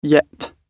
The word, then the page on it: yet: British English